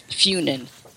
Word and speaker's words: Fyn: And then uh, in the uh, the English version of the island where I live is, is Funen, uh, they spell it F Y N and pronounce it Fune.